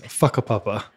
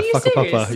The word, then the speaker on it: whakapapa: What is whakapapa and have I pronounced it correctly? – Close. Okay. Yes. whakapapa. – Is that what? Yeah, whakapapa.